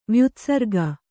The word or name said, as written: ವ್ಯುತ್ಸರ್ಗ